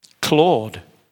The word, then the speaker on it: Claude: To, spelt right, Claude, excuse me, name. My name is spelled C L A U D E, it's French.